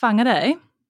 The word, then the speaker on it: Whangarei: It's pronounced Whangarei.